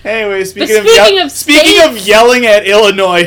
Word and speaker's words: Illinois: They know how to do a silent S! – Obviously! Even though it's technically still pronounced, because it would be Noir, Illinois, but it's whatever. You Illinois. – obviously know how to do the silent S.